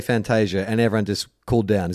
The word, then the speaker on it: Fantasia: So look, here's my understanding is that it came out that the actual pronunciation was Fantasia, and then BT has come out and said, yeah, but you Australianize it and make it Fantasia.